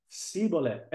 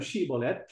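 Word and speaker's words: Shibboleth: The Ephraimites were exposed because they were unable to pronounce Shiboleth. Instead of Shiboleth, it became Siboleth. And so the term Shiboleth came into the English language.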